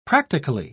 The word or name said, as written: practically